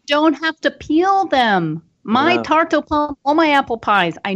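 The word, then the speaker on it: tarte aux pommes: And I would say this tarte au pomme. How do you pronounce it again? – Tarte au pomme.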